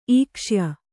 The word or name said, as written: ಈಕ್ಷ್ಯ